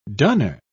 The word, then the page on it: dunner: British English